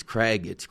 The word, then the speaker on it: Craig: Morton, maybe, but no, he got my son now, who's 10, gets called Greg all the time, and it drives him nuts. – Yeah, me too. – And he says it's Craig. – Craig with a C, hard C.